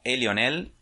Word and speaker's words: Elionel: Em, a mí, si me quieren seguir, me pueden encontrar en Instagram como Elionel. Eso se escribe como si fuera un gritito como eh, E, Lionel.